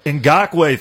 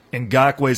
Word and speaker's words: Ngakoue: The Lions, if they want to get to the Super Bowl next year, the most important thing they can do is sign Yannick, oh boy, Ngakoue, thank you. Ngakoue is how you say that.